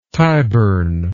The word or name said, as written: Tyburn